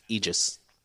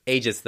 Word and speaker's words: Aegis: So, real quick, before you answer this one, I recently found out it's pronounced Aegis. – Okay, cool. Well, that's ages then is how we're going to do it.